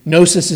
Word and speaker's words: Gnosis: Gnosis begins with a silent G. Gnosis is the Greek word for knowledge.